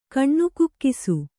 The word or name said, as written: ಕಣ್ಣುಕುಕ್ಕಿಸು